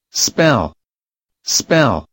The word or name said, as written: spell